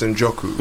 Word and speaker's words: Njoku: It's Njoku. – Njoku, there we go. Tony Njoku, ladies and gentlemen. You have to imagine there's a C there.